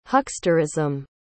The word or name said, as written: hucksterism